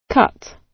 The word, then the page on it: cut: British English